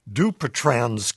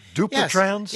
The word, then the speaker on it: Dupuytren's: Did I say that right? Dupuytren's? – Yes. Yeah, well, you said it's close to right.